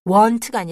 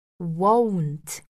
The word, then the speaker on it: won't: 발음 이때 조심하시고요. want가 아니라 won't, won't. 다시 입을 모아주셔야 돼요.